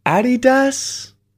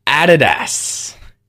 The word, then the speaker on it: Adidas: Adidas? It's Adidas. Adidas. The emphasis is on the first syllable. Adidas. And there's no E, Americans. Adidas. Where's the E in this word? Adidas. It's Adidas.